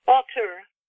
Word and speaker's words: Altur: What's her relationship with her, does she call her master her Altur? – Altur. So I like your pronunciation too.